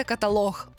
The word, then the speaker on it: каталог: Насправді тут все дуже-дуже просто, тому що є єдиний правильний варіант, і це каталог. Ох, в цьому слові наголос падає на останній склад, так само як діалог чи монолог.